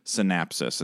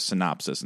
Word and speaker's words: synopsis: I was saying synopsis, but I was pronouncing it synapsis and synapses instead of synopsis and synopses.